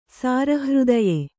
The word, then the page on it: ಸಾರಹೃದಯ: sāra hřdaya